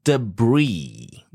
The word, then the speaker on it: debris: So the greatest danger from falling debris, D-E-B-R-I-S, we don't pronounce the...I-S. Okay. We pronounce debris.